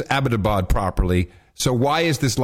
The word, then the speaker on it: Abbottabad: Abbottabad, which is the pr- correct correct pronunciation.